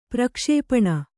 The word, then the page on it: ಪ್ರಕ್ಷೇಪಣ: kannada